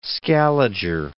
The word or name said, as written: Scaliger